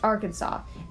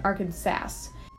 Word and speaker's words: Arkansas: Next, I mispronounced Arkansas in favor of the more phonetic pronunciation Arkansas, going against an act made in 1881 to avoid incorrect pronunciation and spelling of the state name.